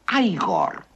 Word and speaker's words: Igor: No, se pronuncia Igor.